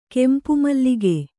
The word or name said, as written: ಕೆಂಪುಮಲ್ಲಿಗೆ